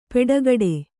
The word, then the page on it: ಪೆಡಗಡೆ: peḍagaḍe